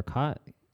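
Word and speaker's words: khat: It's the same amphetamine compound that they find in I think it's pronounced cat or cot, K-A-K-H-A-T, and it's like a like a leafy substance that people in the Middle East sometimes use.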